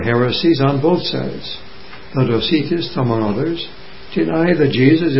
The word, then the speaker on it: Docetists: They were called the Docetists. D-O-C-E-T-I-S-T-S.